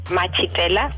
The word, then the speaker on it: Machetela: You can get hold of me through Facebook, Lerato Machitela, which is my name and surname.